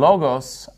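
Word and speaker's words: logos (λόγος): And notice in logos, I'm putting the stress on the first syllable, because that's where the accent mark is.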